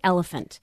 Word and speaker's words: elephant: Here's an example, like the word elephant. – Yep. – Okay. So if it's phonics taught phonetically, it's you blend the letters. You know that PH, the rules in the language, PH makes the F sound.